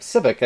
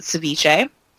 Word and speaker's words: Civic: Civic? – Yep, Civic, I believe, yep. – Civic. I always want to pronounce it Ceviche. Mm. Like the fish dish.